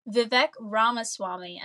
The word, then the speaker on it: Vivek Ramaswamy: Vivek Ramaswamy. And the great thing about his last name is it is pronounced exactly as it is spelled.